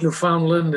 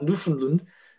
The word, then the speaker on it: Newfoundland: And by the way, for anyone who wants to understand how to say that province, say in your head, understand Newfoundland. That's how you say it. So it's not Newfoundland. It's not Newfoundland, as some of our American friends say.